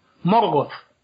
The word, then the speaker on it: Morgoth: Otra, letra, o dos letras, que se pronuncia como en inglés es la TH, que se pronuncia como la Z castellana o como, aunque seamos muy poquitos los hispanohablantes que la pronunciamos así, pero, bueno, o como la TH inglesa, ¿no? – Entonces, por eso hay, hay que decir Morgoth. Morgoth, con nuestra Z.